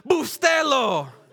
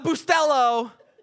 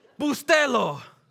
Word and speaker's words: Bustelo: Pronounce it with Bustelo. Not Bustelo, Bustelo.